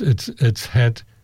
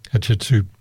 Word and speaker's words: Hatshepsut: when we were told there how to pronounce it, our guide said hot chicken soup. It's not far off how you should pronounce it, but hot chicken soup. But it's Hatshepsut, Hatshepsut. But it sounds a lot like hot chicken soup, doesn't it?